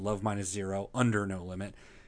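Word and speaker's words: Love Minus Zero/No Limit: You're supposed to pronounce it Love Minus Zero Under No Limit.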